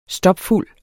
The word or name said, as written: stopfuld